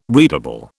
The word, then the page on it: readable: British English